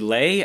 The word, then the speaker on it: Le: It's L-E, Lay. I think you would say it Lay. – Bruce Lay. – But it's trying to be as close to Bruce Lee as possible.